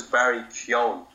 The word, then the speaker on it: Barry Keoghan: His name is Barry Keoghan, if you can see that.